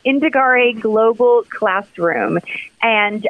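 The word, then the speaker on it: Indagare: What about Indigare? Am I pronouncing that right? – Yep, it's Indigare.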